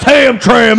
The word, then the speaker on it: Hamtramck: It's pronounced Hamtramck, okay? Especially the Death Park in Homstronks.